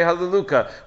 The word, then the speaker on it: Halleluka: So if you assume it's Shem Hashem, you say Halleluka. You're not supposed to just say the Shem Hashem. But if you assume that it's just part of the word, so then you could just say it with its proper pronunciation.